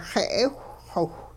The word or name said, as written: xe'ewhxiwh